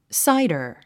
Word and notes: cider: sáidə r; サイダァ

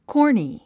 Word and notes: corny: kɔ́rni; コーニィ

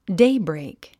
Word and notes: daybreak: déibrèik; デイブレイク

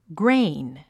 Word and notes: grain: ɡréin; グレイン

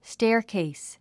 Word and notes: staircase: stέərkèis; ステアケース

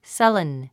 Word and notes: sullen: sʌ́lən; サレン

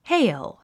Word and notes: hail: héil; ヘイル

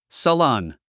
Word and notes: salon: səlɑ’n; サロン

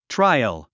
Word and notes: trial: tráiəl; トラィアル

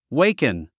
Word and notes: waken: wéikən; ウェイクン

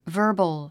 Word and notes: verbal: və́ː r bəl; バーバル

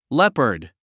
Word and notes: leopard: lépə r d; レパード

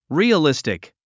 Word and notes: realistic: rìːəlístik; リィアリスティック

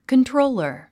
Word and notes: controller: kəntróulər; カントローラー

